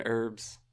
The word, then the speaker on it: herbs: And then you just pronounce the H in herbs.